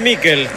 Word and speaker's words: Miquel: ¿Michael o Miquel? Usted que es especialista en nombre. Se escribe Miquel. ¿Cómo le digo? – Decile Miquel.